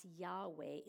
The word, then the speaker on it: Yahweh: Remember We've mentioned it before, but anytime you see that capital L-O-R-D in your Bible, that is the English translation of the Hebrew. We don't know how to say it exactly since it was too holy for them to say, but we pronounce it as Yahweh.